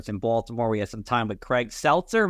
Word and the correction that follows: Setzer: Am I saying his name right, Sean? – Setzer, I believe. – Setzer. Craig Setzer from Royal Caribbean.